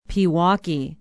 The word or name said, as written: pewaukee